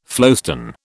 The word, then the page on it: flowstone: British English